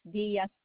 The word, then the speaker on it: Diaz: Rosemary, and then my last name is Diaz Texan.